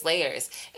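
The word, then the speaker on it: L@yerz: My name is Layers. It is spelled L with the @ Y-E-R-Z. L with the @ Y-E-R-Z.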